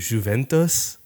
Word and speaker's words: Juventus: A really cool one was Palace and Juventus. Is that how you pronounce it? – Oh, yeah.